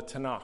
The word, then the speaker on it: Tanakh: So we have the Old Testament in our Bibles, and in the Jewish Bible, it's called the Tanakh.